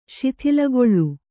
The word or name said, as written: ಶಿಥಿಲಗೊಳ್ಳು